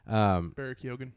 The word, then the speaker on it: Barry Keoghan: Barry Keoghan.